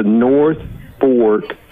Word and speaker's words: North Fork: They fished on the Norfolk for the first time. Norfolk. Did I say it right that time? – There's no Norfolk. North. Just remember, it's the North Fork of the White.